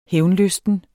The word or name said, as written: hævnlysten